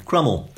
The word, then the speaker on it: Cromwell: And incidentally, gave me another clue to the pronunciation of Crummel. – Right, OK. – Because on more or less day one of the rebellion, some rebels in Dent shouted that we would crumb him and crumb him till he was never so crumbed.